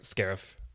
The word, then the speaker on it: Scarif: I mean, at least the very least, the last third when they actually get to Sharif feels very Scarif? Star. Sorry, Scarif. – I mean, Sharif. – No, Scarif. They Scarif. Say it like a bunch of times.